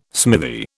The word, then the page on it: smithy: British English